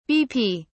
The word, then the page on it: bp: British English